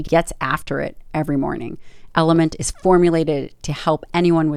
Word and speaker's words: LMNT: I'd like to thank another sponsor of the podcast, and that's Element, pronounced Element, but actually spelled L-M-N-T.